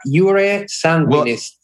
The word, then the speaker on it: jure sanguinis: Now, we have the law of sanguine. Am I saying that right? – Jure sanguinis. The law of the blood.